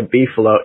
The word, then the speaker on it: beefalo: So a beefalo, in case people don't know, I didn't mispronounce that. It's actually a beefalo.